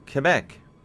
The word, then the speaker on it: Quebec: You say Quebec? – Quebec. – Almost with a K sound. Yeah, I've always pronounced the Q.